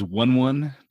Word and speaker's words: Wun Wun: Uh, all right, so that's right, and um, now the Giants' name was Wun Wun.